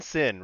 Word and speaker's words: Cincinnati: M-I-S-S-I-S-S-I-P-P-I. Cincinnati is spelled, so if you say it, sin, right? It's sin twice, but it's a C, so it's the same thing. It's C-I-N-C-I-N, natty, N-A-T-I.